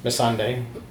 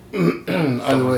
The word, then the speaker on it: Missandei: Missandei, I believe it's pronounced. – Okay. Missandei. – Missandei.